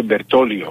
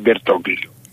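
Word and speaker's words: Bertoglio: Ο παίκτης του Άρη λέγεται Μπερτόλιο, όχι Μπερτόγλιο. – Γιατί? – Το Ζ δεν προφέρεται στα Ιταλικά.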